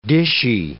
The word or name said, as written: dishy